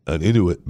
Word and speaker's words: Inuit: And then in 2010, an Inuit man, is it Inuit? I-N-U-I-T? Inuit man? I-N-U-I-T. Inuit, right? Make sure I'm pronouncing that right.